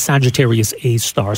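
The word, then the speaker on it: Sagittarius A*: Prior to this, what did we know about Sagittarius A star, which I guess is how we pronounce it. It sort of looks like it's A with an asterisk, but I think we call it Sagittarius A star.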